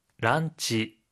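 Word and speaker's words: 란치 (ランチ): 런치의 러 부분은 라, 디너의 너 부분은 나로 발음합니다.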